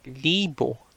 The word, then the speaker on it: libo: For 1,000, we use the word libo, libo. So libo is spelled as L-I-B-O. Again, L-I-B-O.